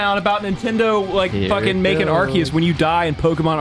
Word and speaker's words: Arceus: First of all, it's pronounced Arceus, you uncultured swine.